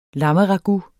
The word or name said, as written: lammeragout